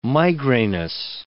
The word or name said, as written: migrainous